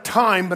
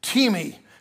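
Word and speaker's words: timi (τιμή): The Greek word for honor in the New Testament, where it says give honor where honor is due, is the Greek word, it looks like time, but it's actually timi. It's pronounced timi.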